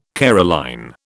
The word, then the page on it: Caroline: British English